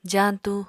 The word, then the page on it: jaañtu: apatani